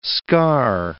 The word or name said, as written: scar